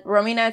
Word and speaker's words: Romina: And Romina, it's spelled R-O-M-I-N-A.